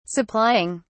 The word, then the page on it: supplying: British English